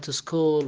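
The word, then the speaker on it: school: أما اللي سألوا عن كلمة السي و اتش تنطق بحرف الكاء، حالات شاذة وقليل من الكلمات التي تنطق بحرف الكاء، يعني مثل ما تفضلت كلمة سكول وكلمة كروم وكلمة كيمستري، هذه كلها تأتي سي اتش سي اتش مباشرة، يأتي بعد السي اتش لكنها تنطق حرف الكاف.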